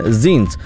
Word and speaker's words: zines: Do you know what zines are? Spelled as Z-I-N-E-S. I know. जैसा लिखा जाता है वैसा प्रोनाउंस नहीं होता है लेकिन जीन्स they are small self published and independent booklets made for personal expression rather than for profit.